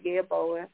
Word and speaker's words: Yeboah: My name is Mayat Yeboah. It's spelled M-A-A-T. Last name is spelled Y-E-B-O-A-H.